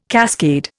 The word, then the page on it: cascade: Indian